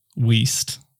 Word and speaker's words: Wiest: And I've been saying her fucking name wrong the whole time. So if you've heard us talking about The Mountain Is You by Brianna Wiest, it's actually Brianna Wiest.